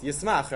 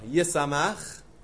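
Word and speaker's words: Yisamach (יִשְׂמַח): What would you say the next word is? Yismach, right? No. Yisamach.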